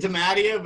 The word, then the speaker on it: Dimattia: I, I, I say Damatia, but I think that's you actually got a, probably a more authentic Italian pronunciation out of it.